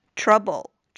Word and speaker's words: trouble: 这个词大家应该都很熟悉，但是注意这个小口的尖角啊，发音的时候要注意这个元音 trouble, 嘴巴不要过大。